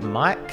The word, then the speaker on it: MMYYKK: Coming up next, we have Mike. That's spelled M-M-Y-Y-K-K.